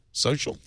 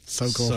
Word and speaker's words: SO.CL: How do you pronounce that? Sokol? Social? – Sokol.